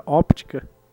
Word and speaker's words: óptica: Fibra óptica também, né? Se você for aí do Portugal. – Não, mas descobri que o, o P é mudo.